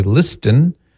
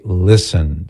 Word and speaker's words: listen: It just doesn't sound right if you pronounce the T. We don't say listen. – No. – We say listen.